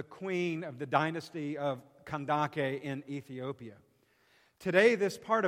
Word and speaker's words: Kandake: It looks like Candace when you read it in English, but it's pronounced in the Greek Kandake.